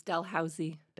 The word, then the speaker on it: Dalhousie: Dalhousie University? – So close, Dalhousie.